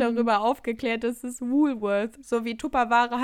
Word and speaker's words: Woolworth: Eigentlich heißt es Woolworth. Ah! oh mein Gott. Wie nennst du das? Ja, auf Deutsch ausgesprochen, halt Wolwort hieß es immer.